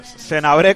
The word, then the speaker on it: Senabré: Senabré, con acento en la E, lo tiene muy claro.